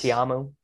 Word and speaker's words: Ta'amu: Ta'amu.